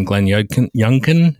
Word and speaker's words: Glenn Youngkin: I think you saw that in Glenn Youngkin. Is that how you pronounce his name? – Yes.